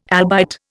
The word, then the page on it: albite: Indian